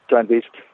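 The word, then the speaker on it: strandbeest: So speaking of these beach animals or strand beasts, I guess, is that how you say it in Dutch? Strand beast? – Yes, strand beast.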